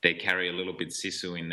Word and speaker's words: sisu: Yeah, I, I noticed on your website, and uh, your bio, it, it says use sisu, not sugar.